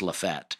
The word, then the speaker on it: Lafayette: Lafette, Alabama, spelled Lafayette, pronounced Lafette.